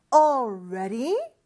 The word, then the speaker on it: already: 자, already 발음 연습 조금 해볼까요? All 하면서는 사실 혀가 윗니 뒤쪽에 와서 붙어야 돼요. 그러면서 떼면서 ready 이렇게 발음을 하지만 사실 바로 옆에 있잖아요. L하고 R 이 두 철자가.